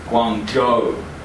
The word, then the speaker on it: Guangzhou: ZH is pronounced j. OU is pronounced O. So when you see a place, Guangzhou.